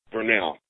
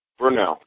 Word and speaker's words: Burnell: Say your first name. I want to get it right. – Brunel. B-U-R-N-E-L-L. – Brunel? – Brunel. You're close. Brunel.